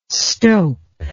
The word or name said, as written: stope